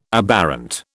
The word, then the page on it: aberrant: British English